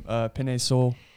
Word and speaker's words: Penei Sewell: My Bengals are going, sorry if I mispronounce his name, Penei Sewell.